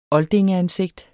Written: oldingeansigt

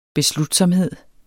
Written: beslutsomhed

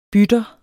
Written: bytter

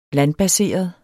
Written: landbaseret

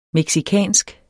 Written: mexicansk

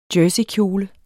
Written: jerseykjole

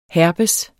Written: herpes

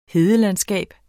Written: hedelandskab

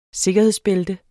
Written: sikkerhedsbælte

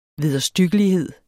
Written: vederstyggelighed

